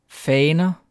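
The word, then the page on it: faner: Danish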